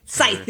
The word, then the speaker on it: scythe: How do you pronounce that? It's a little metal. – Like a scythe? – Scythe, or, yes.